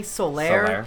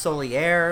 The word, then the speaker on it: Solaire: I say Solaire, but I've heard it pronounced Solier, okay.